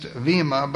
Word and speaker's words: Bema: Now, as we begin this study of the Bema Seat of Christ, it's perhaps properly pronounced Vima, but we'll use Bema because that's what we've always been used to.